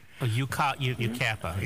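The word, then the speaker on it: Yucaipa: And some friends of mine live in, how you pronounce it, Waikapa, Yakapa, whatever it is. – Yukapa.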